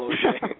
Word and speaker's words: Losier: There is a hockey player named Michael Losier, but he's not famous. But when he becomes famous, then people will know how to pronounce my name.